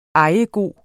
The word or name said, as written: ejegod